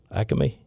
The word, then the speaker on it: Acme: Yeah, three syllables. Acme.